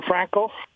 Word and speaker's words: Franchi: Or Franco, F-R-A-N-C-H-I. It's pronounced different than what it's spelled.